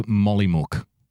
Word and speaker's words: Mollymook: He's, by the way, the way you pronounce that town name there, that's Mollymook. Mollymook.